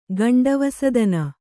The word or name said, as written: ಗಂಡವಸದನ